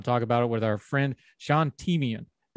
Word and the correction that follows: Teema: Did I get it right? I was practicing. – Teema. – See, I'm a hillbilly. We can't do those vowel sounds.